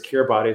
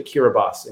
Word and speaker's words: Kiribati: So he was very eager to talk about the island of Kiribati, which is spelled Kiribati. And I've always thought of it as Kiribati. It's this island in the middle of the Pacific, but it's actually Kiribati.